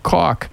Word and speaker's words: Koch: And if you like yourself a little Greg Koch, and that's spelled K-O-C-H, pronounced like chalk, smelled like K-O-C-H, spelled like K-O-C-H.